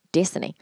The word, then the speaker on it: Dasani: oh, that's a Disney. Disney. And I always think of that as how I'm like, what the fuck? I'm like, Dasani. He's like, that's how you say it.